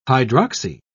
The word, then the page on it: hydroxy: British English